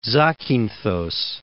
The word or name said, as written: Zakinthos